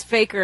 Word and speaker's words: fakir: I always thought it was fakir, but you know. – Yeah, that's what I thought too, but maybe it is faker. – That's me being pretentious about my pronunciation. – It's faker in American.